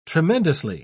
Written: tremendously

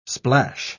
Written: splash

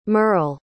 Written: Myrle

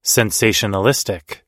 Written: sensationalistic

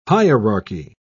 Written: hierarchy

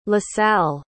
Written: lasalle

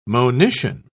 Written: monition

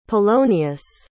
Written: polonius

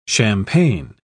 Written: champaign

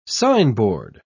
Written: signboard

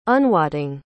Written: unwotting

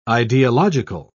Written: ideological

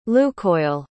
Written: Lukoil

